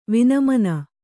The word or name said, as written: ವಿನಿಮ್ನ